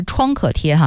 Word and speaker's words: 创可贴: 我们先来更正一个读音，是创可贴哈。